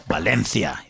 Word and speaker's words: Valencia: The next one's going to be in Valencia. Well, Valencia, if you want to pronounce it correctly.